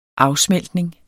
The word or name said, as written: afsmeltning